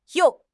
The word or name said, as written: you4